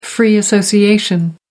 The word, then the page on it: free association: American English